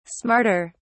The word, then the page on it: smarter: British English